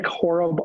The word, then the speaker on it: Horob: Nick Horob, all one word. My last name is spelled H-O-R-O-B as in boy.